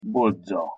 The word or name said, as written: bodza